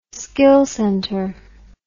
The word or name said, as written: skill centre